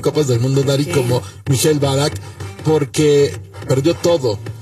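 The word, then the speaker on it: Michael Ballack: ¿Cómo se dice bien? ¿Mijael Balak? – Mijael Balak, sí.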